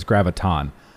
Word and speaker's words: graviton: So, I said it incorrectly, by the way. It's graviton.